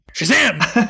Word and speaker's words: Shazam: Shazam!